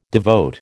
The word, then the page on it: devote: American English